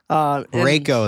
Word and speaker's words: Reiko: Reiko is how you pronounce her name, by the way.